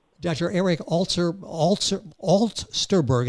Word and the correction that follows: Alsterberg: and I'll spell that because I didn't say it correctly, A-L-S-T-E-R-B-E-R-G, Alsterberg, author of Healing and Transformation, Moving from the Ordinary to the Extraordinary.